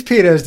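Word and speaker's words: Peatos: These pedos didn't say they're Cheeto substitutes. – The name is pedos. Rhymes with Cheetos.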